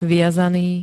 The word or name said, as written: viazaný